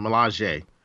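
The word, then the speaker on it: Milaje: How do you say Melange? – Melange. – Melange? – Without the N, Melange.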